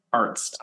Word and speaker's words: Arzt: Arzt.